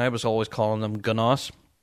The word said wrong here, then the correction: Gnoss: But the G is silent. So G-N-O-S-S, NOS.